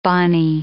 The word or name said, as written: bonnie